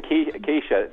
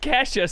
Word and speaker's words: Kesha: Me and Keisha. It's on, Keisha. – Well, it's pronounced Kesha, so maybe you're... – I'm just saying, I'm mispronouncing it to piss her off.